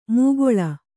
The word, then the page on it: ಮೂಗೊಳ: kannada